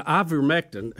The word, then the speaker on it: ivermectin: Now, ivermectin, I'm not saying it right, but that's close as I'm going to get.